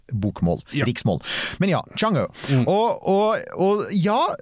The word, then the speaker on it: Chang'e: Eh, og den heter jo da Vi skriver det Chang E5, men jeg så en kineser si nå må dere skjønne, dere får prøve å uttale det riktig, og det er Chang O. E det er sånn de sier det.